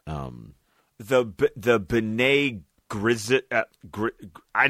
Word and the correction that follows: Bene Gesserit: The Bene Gesserit. – Yeah, Bene Gesserit, yes.